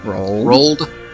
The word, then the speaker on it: Roald: Rolled? Like rolled oats? – Roald Dahl. Okay, because it looks really weird when you see the spelling. Roald Dahl. Exactly.